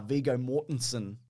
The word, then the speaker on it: Viggo Mortensen: apparently they wanted Viggo Mortensen, which is how you pronounce his name, Mason.